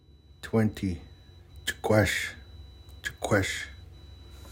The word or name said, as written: chexwkwesh qe ihixw